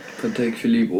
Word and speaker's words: Patek Philippe: Also feierst du diese krassen Uhrenmarken wie Rolex, Audemars Piguet, Patek Philippe, wenn ich das jetzt richtig ausgesprochen habe. – Patek Philippe, Omega.